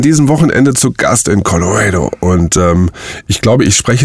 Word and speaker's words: Colorado: Colorado.